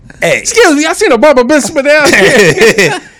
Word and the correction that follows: bar mitzvah: How do you pronounce that word, by the way? Just help me out. – Bar mitzvah. – Oh, bar mitzvah.